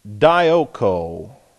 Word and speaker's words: dioko: But even in looking at the Greek, this is a Greek word, dioko, D-I-O-K-O, dioko. This is D-I-O-K-O, dioko. These are both long O's, omegas.